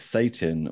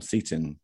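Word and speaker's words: Seyton: and Satan, or Seton, depending on however you like to pronounce it.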